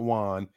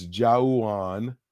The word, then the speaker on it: Juwan: Juwan Jennings, spelled with an A-U, is not Juan. It's Juwan.